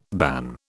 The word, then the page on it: ban: British English